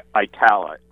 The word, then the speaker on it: Itala: Give us a little background on the it's Italia. Italia, is that how you pronounce it? – Italia, I-T-A-L-A. – Okay, Italia. Okay.